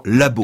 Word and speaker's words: lab: Depuis quelque temps, j'entends lab. Mmh. C'est-à-dire que c'est un emprunt, puisque l'anglais abrège en lab. – Et aussi un gain de temps. – Un gain de temps. – Time is money. – Time is money. Donc, une seule syllabe, comme en anglais, laboratory, hein, naturellement, et une consonne finale.